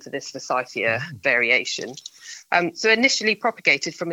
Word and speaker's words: forsythia: Now, we've got some things that are being referred to as star plants, which sound really exciting, including, and I hope I pronounce this correctly, forsythia. Tell me about this. This sounds really interesting. – That is correct.